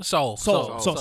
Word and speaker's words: Seoul: Did I say that right? Seoul? – Seoul. – See, I was just asking the homie earlier. I was like, is it Seoul or Seoul? – Yeah, no, Seoul. Yeah, ready to Seoul, man.